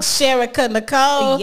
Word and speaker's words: Sherika Nichole: Sherika Nicole.